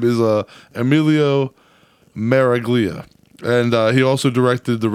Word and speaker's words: Miraglia: Emilio Miragalli. – Miraglia.